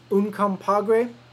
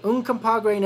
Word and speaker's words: Uncompahgre: It's actually pronounced Uncompahgre. – Uncompahgre National Park.